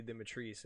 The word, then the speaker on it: Demetrice: They just she just said Lady Demetrice, and I was like, oh, I didn't know that's how you actually pronounce it. But now we know how to pronounce it, so that's cool.